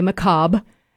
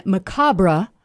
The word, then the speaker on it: macabre: So in America, we usually say macabre, but if you say the other version, macabre, then that is the British English pronunciation, which is closer to the original French, which is macabre.